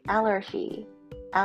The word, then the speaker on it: allergy: アレルギーの発音ちょっと癖がありますよね。アラジーって感じです。アラジンみたいなイメージ。アラジーアラジンのんなしですね。